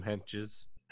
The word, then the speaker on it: Hentges: Sam Hentges.